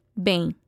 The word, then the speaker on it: bem: You can also respond by saying, estou muito bem. I'm very well. Estou means I am. Listen and repeat after him. – Estou muito bem. – Notice the am sound in the word bem.